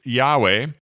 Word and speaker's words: Yahweh: And in Hebrew, they don't really have vowels, so it's it's four uh, consonants put together, but it's pronounced Yahweh, and it basically means I am.